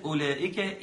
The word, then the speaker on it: أولئك: كلمة أولئك، الواو عليها علامة. العلامة دي ايه؟ دي علامة من علامات الرسم العثماني، تدل على أن هذا الحرف لا ينطق أصلا.